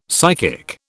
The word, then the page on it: psychic: British English